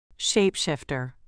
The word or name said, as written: shapeshifter